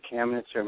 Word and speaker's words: Kamnitzer: So my name is David Kamnitzer. My last name is spelled K-A-M as in Mary, N as in Nancy, I-T-Z-E-R.